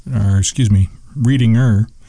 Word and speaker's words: Readinger: Margie Irene Reedling, Reedinger, age 76, and that's spelled Readinger.